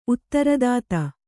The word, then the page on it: ಉತ್ತರದಾತ: Kannada